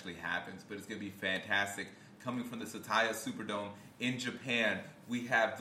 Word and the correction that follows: Saitama: The what Superdome? Saitama. – Saitama Superdome. I knew I said it wrong.